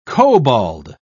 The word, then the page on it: kobold: British English